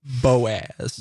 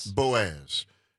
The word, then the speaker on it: Boaz: And Boaz, is that how it's pronounced? – Boaz. – Boaz. Hey, good morning to you. How you doing? Like, thank you very much for the call. – Kind of like Joan Boaz.